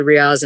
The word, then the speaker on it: Riaz: I, will get to Riaz in a second because I really like that. Am I saying his name properly? Yeah, yeah, yeah.